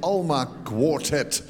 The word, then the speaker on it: Alma Quartet: Dat was het eerste deel uit het strijkkwartet van Claude Debussy, gespeeld door het Alma Quartet, op zijn Engels. Spreek je dat uit?